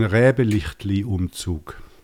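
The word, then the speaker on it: Räbeliechtli-Umzug: Hier in der Schweiz gibt es, ach, Joel, korrigiere mich bitte bei der Aussprache, gibt es den Räbelichtli-Umzug. – Ziemlich gut ausgesprochen, ja.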